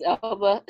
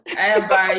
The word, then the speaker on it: Elba: Idris Elba. – Elba, yes.